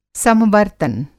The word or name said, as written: समवर्तन